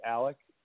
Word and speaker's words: Alec: It's Alec, Alec. Like Alec Baldwin or Smart Alec, Alec, and the last name is Stern, like Howard, Stern.